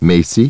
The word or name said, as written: Macy